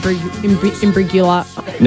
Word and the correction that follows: Imbruglia: Soft G. – Imbriglia. – Bang. – Imbruglia. Natalie Imbruglia. – Beautiful.